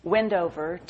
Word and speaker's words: Windhover: It's Windhover, but, you know, it's like the British. You don't pronounce the H. Wendover, to Christ my Lord.